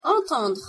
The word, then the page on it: entendre: French